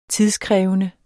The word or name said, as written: tidskrævende